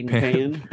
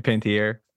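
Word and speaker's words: Panettiere: I'll call her Hayden Pan. – And Pantier.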